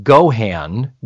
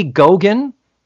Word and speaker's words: Geoghan: Now, this was written by a man named, I believe it's pronounced Jim Gohan, G-E-O-G-H-A-N. That sounds like an Irish name. Gohan, would you say? It could be Gogan.